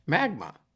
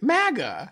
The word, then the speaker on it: MAGA: Magma. – Magma. MAGA. MAGA? How do you say it? – America Great. MAGA.